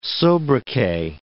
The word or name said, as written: sobriquet